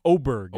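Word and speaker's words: Åberg: Oberg is how you pronounce his name.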